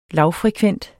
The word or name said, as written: LAVFREKVENTE